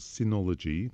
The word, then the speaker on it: Synology: So it's from a company called Synology. That's spelled with a Y or two Ys, Synology.